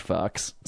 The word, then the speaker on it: Fuchs: F-U-C-H-S.